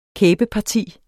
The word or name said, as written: kæbeparti